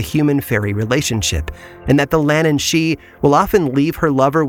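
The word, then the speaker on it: Lhiannan Shee: It's spelled differently but pronounced the same, Lannan she, although this creature is far more dangerous than her Irish counterpart.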